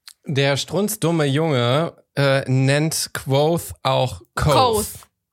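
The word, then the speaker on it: Kvothe: Und dann sagt er, das spricht man Quoth aus, das E ist stumm.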